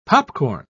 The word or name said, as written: popcorn